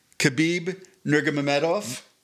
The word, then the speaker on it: Khabib Nurmagomedov: we're going to talk about UFC 254 featuring Khabib Nurmagomedov. Yeah, that was like 100% perfect pronunciation, guys. That's how you say it.